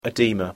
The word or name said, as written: oedema